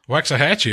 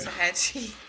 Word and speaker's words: Waxahachie: How do you pronounce that? Waxahachie, it looks like. – Waxahachie. – Is that spelled right? Waxahachie? – Yeah.